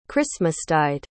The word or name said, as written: christmastide